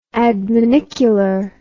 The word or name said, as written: adminicular